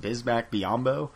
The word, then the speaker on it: Bismack Biyombo: Getting stuffed by Bohemian Matimbio. – I think it's pronounced Bismack Biyombo. – Yeah, that's close enough.